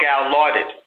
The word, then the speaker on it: Gallaudet: Back in 1988, the eyes of our country were upon a university, and I believe it's pronounced Gallaudet in Washington, D.C., or Gallaudet, rather, in Washington, D.C.